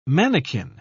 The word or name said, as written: mannequin